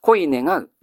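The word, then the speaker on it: こいねがう: こういうものの読み方どうなりますか？恋願うですね。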